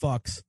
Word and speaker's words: Fuchs: Pretty sure it's pronounced fucks.